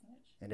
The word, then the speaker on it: niche: And I always want to say niche, but I had to look it up before we came on today just to make sure I was saying it correctly. And it's, is it niche? – Yes, it's niche.